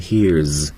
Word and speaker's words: hears: 那么 hear 这个单词再加 s 的形式的时候要注意它的第三声读成 hears，因为元音结尾的音素呢加了 s 要读成 z。